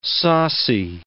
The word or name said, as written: saucy